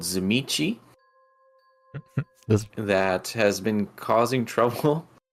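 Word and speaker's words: Tzimisce: Zimichi. – Zimichi. – And it's spelled like Tizimisk, so it doesn't help.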